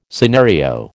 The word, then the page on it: scenario: American English